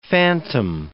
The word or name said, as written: phantom